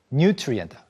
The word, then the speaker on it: nutrient: 그 다음으로 영양소, nutrient. 아까 영양가 높은이라고 할 때는 nutritious라고 그 강세가 한음절 뒤에 있었어요. 근데 이건 한음절 짧기 때문에 nutrient가 됩니다.